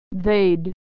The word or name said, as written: they'd